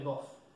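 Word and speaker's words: GoF: Este es el libro que se conoce como el libro de Goff, o, sí, el libro de Goff normalmente, se dice así.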